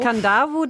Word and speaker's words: Kadavu: Mm, mm, Und Kandavu, das wird also Kadavu geschrieben mit D, aber man spricht es ND aus.